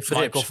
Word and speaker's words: Verrips: Jij, gaat het nooit goed kunnen nee, uitspreken. Michael Verrips. – Nee, Verrips. Michael Verrips.